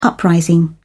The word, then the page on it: uprising: British English